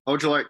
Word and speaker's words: Ojulari: Technically, how do you pronounce the defensive end there? I forget his name. – Which one? – Aziz? Ojulari? – Ojulari.